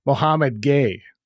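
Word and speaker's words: Mohamed Gueye: For what it's worth, the proper pronunciation is Mohammed Gay.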